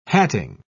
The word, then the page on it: hatting: British English